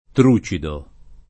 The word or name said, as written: trucido